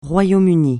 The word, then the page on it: Royaume-Uni: francais